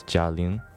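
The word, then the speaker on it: 贾玲: I do know that this movie was written, directed, and produced by a leading female comedian in China named Jia Ling.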